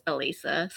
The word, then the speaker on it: Alissa: I'm used to it. It's how it's spelled. Everybody says Alissa, but it's Alissa.